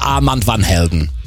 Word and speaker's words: Armand Van Helden: Ich habe irgendwann mal Armin gefragt und er so, sag's im Englischen, dann ist es viel, viel einfacher. Armin Van Buuren. Deswegen sagen wir Armand Van Helden.